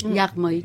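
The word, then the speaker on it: Yaghmaei: Je ne sais pas si je le prononce bien, mais vous serez là pour me corriger. Kourosh Yagmaei. – Yagmaei, tout à fait.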